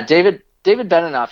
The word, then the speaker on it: David Benioff: David Benioff? I don't know how to pronounce his name. – Yeah, David Benioff.